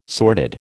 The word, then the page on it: sordid: American English